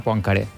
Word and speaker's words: Poincaré: Poincaré, perquè és francès, crec que hauríem de pronunciar Poincaré. Però ja seria d'un esnovisme... – Sí, avales aquesta pronunciació? – Poincaré.